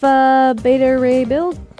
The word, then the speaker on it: Beta Ray Bill: He could pull off Beta Ray Bill.